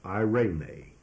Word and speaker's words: eirene: The word peace in Greek is the word eirene, E-I-R-E-N-E. It's pronounced eirene, E-I-R-E-N-E, eirene. And this is a noun that comes from the verb eiro, E-I-R-O, and the word eiro means to join.